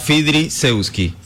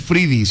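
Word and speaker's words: Fidriszewski: El apellido se pronuncia Fidri Sewski. – No es Fridi, sino Fidri Sewski. – Es que mire, es F-I-D-R-I. S-Z-E.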